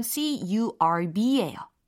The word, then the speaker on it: curb: 어, 철자로 설명을 해드리자면 C U R B예요. C U R B 발음은 curb, curb 이렇게 표현하시면 되겠어요.